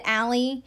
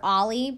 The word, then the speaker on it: Ahli: Allie or Ollie? I think she pronounces it Allie, but other people call her Ollie, but it's spelled A-H-L-I.